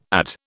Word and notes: add: British English

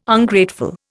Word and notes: Ungrateful: Indian

